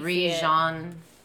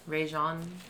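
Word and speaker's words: Regé-Jean: It's like Rajon. Rajon. R-E-G.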